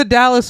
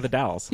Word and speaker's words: Dalles: There's a Dallas, Oregon. It's Dallas, Oregon and the Dalles.